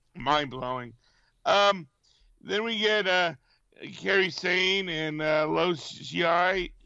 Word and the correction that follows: Io Shirai: How do you say her name again? – Io Shirai. – Io Shirai.